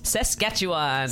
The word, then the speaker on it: Saskatchewan: And it is the correct pronunciation is? – Saskatchewan. – Saskatchewan. – I just don't say it. – What did you say, Nussy? – I said Sasquatch.